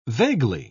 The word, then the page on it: vaguely: British English